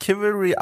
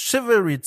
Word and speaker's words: Chivalry: Es wird tatsächlich nicht Chivalry ausgesprochen, wie ich lange Zeit peinlicherweise dachte. Es ist Chivalry 2, ein Multiplayer-Online-Only-Spiel, in dem sich Ritter auf den Kopf hauen.